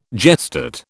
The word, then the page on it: gestate: British English